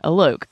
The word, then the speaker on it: Alok: I know I pronounced their first name Alok. I know that's right because they said it.